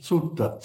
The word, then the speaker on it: Suetta: If his name is, it's S-U-E-T-T-A, Sutta, is that how you pronounce it? S-U-E-T-T-A. – Sutta. – Sutta. See, he had it better than I did.